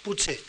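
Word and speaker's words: potser: Puche, tal vez, quizás. esta que escribimos TS, pero que no pronunciamos TS, pronunciamos una CHE, una CHE africada, PUCHE.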